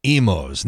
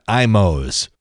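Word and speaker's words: Imo's: Here's how you say Emos, not Imos.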